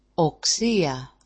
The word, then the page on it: oksia: oksi-a